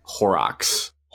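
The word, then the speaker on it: Horrocks: I went to Horrox on Sunday. Nice grocery store. – Did you tell the people what happened when you drank? – Where? – Horrox.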